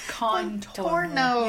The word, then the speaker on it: Contorno: It's Contorno. – You said corn. Put an R in there. – I'm not Italian. I am doing this phonetically. – But you didn't even say it right. You put an extra R in there.